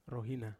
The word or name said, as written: rohina